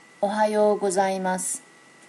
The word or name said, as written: ohayo gozaimasu